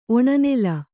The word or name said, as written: ಒಣನೆಲ